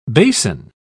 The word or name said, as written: basin